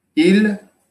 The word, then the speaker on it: ils: If I say "ils vont," now you understand that we're talking about they, masculine plural, just because of the verb you are able to know. But there is no difference in the pronunciation.